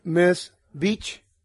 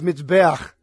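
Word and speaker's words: Mizbeach: Like, if you pronounce it, it says Miss Beach, but it's Mitzbeach.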